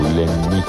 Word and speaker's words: Лянни: Квартира принадлежала его другу, манекенщику Лянни. С ударением на «и», уточнил он.